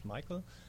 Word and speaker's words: Michael: Michael, I'm just going to say Michael. I remember that part, but you said at home in Germany you pronounce it another way, right? – That's true. – Okay. – Michael is fine.